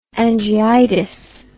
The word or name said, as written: angiitis